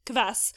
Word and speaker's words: kvass: So this is K-V-A-S-S. Do you pronounce it Kvass? How are you saying it? – So I know in America it's pronounced as the way you pronounce it. – Right. – I pronounce it as Kvass because I'm Polish born, and in Polish and in Russian it means acid. It's hard for me to pronounce it otherwise. So I say Kvass.